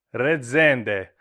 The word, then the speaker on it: Rezende: How do you say your last name? – Rezende. – Rezende. I want to say it Italian almost. Rezende. – Bravo.